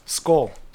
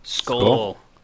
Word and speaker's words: skål: Skål. – Skål.